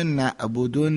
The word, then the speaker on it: نعبد: نه اتفاقا نباید خیلی هم غلیظ گفت، نباید خیلی دیگه غلیظ تلفظ بکنه، همین اندازه که مشخص بشه که اینجا نعبدو نیست، نعبدو نعبدو نعبدو. ببین یه مقداری حرف عین یه حالت، یه گردشی، یه پیچشی توی حالت حلق و گلوم پیدا میکنه، صدای این درست میشه، نعبدو نعبدو.